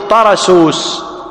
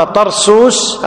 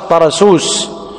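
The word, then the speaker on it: طَرَسُوس: قولا واحدا تقول طرسوس، وأما طرسوس هذا خطأ وهو مشهور، الناس كثيرا ما ينطقونه طرسوس، غلط، طرسوس، والنسبة إليه طرسوسي.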